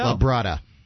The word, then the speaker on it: Fuenlabrada: Is it F-U-E-N? – Yeah. – Fuenlabrada? – Labrada. – Brava? – Yeah, with a D. Inside of a Spanish word, the D is pronounced with a theta.